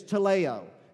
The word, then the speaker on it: teleo: In the Greek, it is teleo, is how it's pronounced.